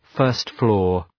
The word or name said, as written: first-floor